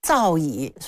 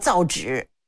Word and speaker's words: 造诣: 那我们经常有人把造诣说这个人的造诣很高念成造纸是吧，他是因为那旁边那个是纸，他不知道古代的纸就可以念意的，他不知道啊，他就念错，念半边字儿很让人吃亏的。